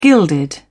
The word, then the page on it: gilded: British English